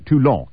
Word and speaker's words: Toulon: In the summer of 1995, Front candidates won mayoral races in three fair-sized southern towns, Marignan, M-A-R-I-G-N-A-N-E, Orange, spelled like orange, and Toulon, capital T-O-U-L-O-N.